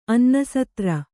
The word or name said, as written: ಅನ್ನಸತ್ರ